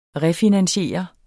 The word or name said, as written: refinansiere